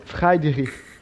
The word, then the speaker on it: Friedrich: Pronuncia em alemão, pronuncia em alemão, vai. Friedrich é alemão, como é que pronuncia? – É. Friedrich. Fre... É, perdão, perdão, desculpa, desculpa os ouvintes, é Friedrich.